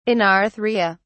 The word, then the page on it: anarthria: British English